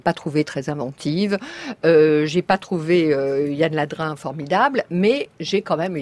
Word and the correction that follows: Yannick Landrin: Non, j'ai écorché Yannick. – Son nom. – Yannick Landrin. Yannick.